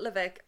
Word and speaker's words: Levesque: The way I've heard it pronounced is Doreen Levesque. And it's Lavesque. I feel like you'd pronounce the S, but I've heard it pronounced as Levesque. And spell it again. – Lavesque. – I'd say Levesque. – Yeah. – Okay, Levesque. We'll go with Levesque.